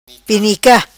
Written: Penica

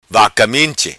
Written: vaca minchi

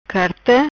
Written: Carta

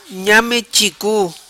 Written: Nhame Chicu

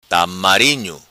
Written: Tamarinho

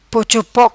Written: Pochopoc

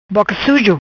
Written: Boca Sujo

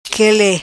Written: Quele

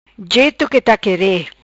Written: Jeto qi ta quere

